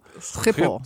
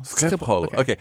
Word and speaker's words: Schiphol: It's not, it's Pol. – Schiphol. – Schiphol. Okay, so the first thing that we have learned on this podcast is how to pronounce Schiphol.